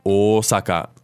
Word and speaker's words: 大阪: So we have 私, I, wa, the topic particle, meaning that we're talking about 私, I, Osaka, the city Osaka, which in Japanese we pronounce with a long O at the beginning. Osaka.